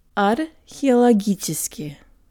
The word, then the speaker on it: археологический: Археологически.